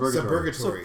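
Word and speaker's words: Suburgatory: Subgatory.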